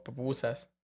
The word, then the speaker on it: pupusas: And you guys, you guys tell me if I'm saying it wrong, bro. Pupusas. – Pupusas. Yeah.